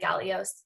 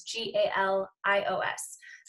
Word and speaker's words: Galios: So at Alyssa Galios. And Alyssa is spelled A L Y S S A, and then Galios is G A L I O S.